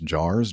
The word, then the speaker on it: JAARS: We are part of an organization called, I guess, pronounced JARS, J A A R S.